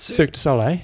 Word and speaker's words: Cirque du Soleil: How do you say that? – Cirque du Soleil. Yeah.